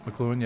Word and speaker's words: McLuhan: Like, it just, I felt like Elul, since he was before McLuhan, that's how you say his name, right? – Yep, McLuhan, yep.